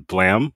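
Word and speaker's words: Blame!: But prior to that, he worked on Blam, which is spelled Blame with an exclamation point.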